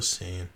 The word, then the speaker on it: Saiyan: You say Saiyan? – He wrote Saiya. S-A-I-Y-A. I think he meant Saiyan. – S-A-Y-I-A. That's how they pronounce it in Japanese. It's still Saiyan.